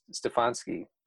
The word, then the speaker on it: Stefanski: Stefanski.